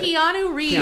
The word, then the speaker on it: Keanu Reeves: I cannot pronounce this name, and I know it's easy. Conal Reeves. I can't pronounce it. – Keanu Reeves.